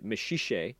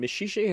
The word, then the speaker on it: Maxixe: is a boy named Maxixe, which is spelled M-A-X-I-X-E.